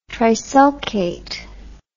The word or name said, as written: trisulcate